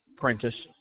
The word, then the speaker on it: Prentice: C-A-S-T-L-E. – Castle. – Yeah. – First name? – Prentice. – Can you spell that for me? – P-R-E-N-T-I-C-E.